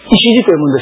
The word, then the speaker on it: 礎: 平和の基礎の祖と書いてね、礎と読むんですけども、